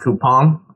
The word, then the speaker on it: Coupang: You'd think it's called coupang, but it's actually pronounced coupon.